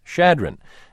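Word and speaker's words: Chadron: mispronounced the town in Nebraska that should be pronounced Shadron and characterized as flat the surrounding area, which, Ms. Montello says, is, quote, actually quite hilly.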